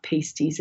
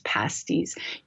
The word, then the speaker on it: pasty: I know how to say pasty. It's not pastries, it's not pasties, it's pasties, Youper food of the gods.